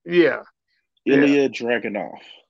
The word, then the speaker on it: Ilya Dragunov: It was Bron Breakker versus JD McDonough versus, like I said, I can't say his name, but Asia Dravonic. – Ilya Dragunov.